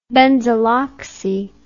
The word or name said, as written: benzyloxy